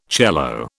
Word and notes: cello: British English

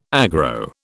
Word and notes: aggro: British English

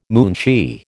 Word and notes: moonshee: American English

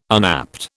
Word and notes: unapt: British English

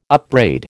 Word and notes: upbraid: American English

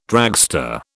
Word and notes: dragster: British English

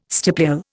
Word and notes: stipule: Indian

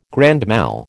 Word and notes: grand mal: American English

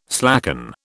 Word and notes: slacken: British English

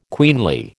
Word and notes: queenly: American English

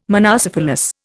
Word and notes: monocephalous: Indian

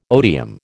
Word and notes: odium: American English

